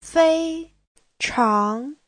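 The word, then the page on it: feichang: fei1chang2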